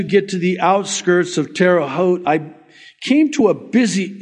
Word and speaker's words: Terre Haute: Last time I totally mispronounced that. Terre Haute. I just want you to know I know how to pronounce it now.